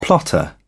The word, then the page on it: plotter: British English